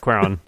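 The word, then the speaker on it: Cuarón: Cuaron. – Cuaron, yeah.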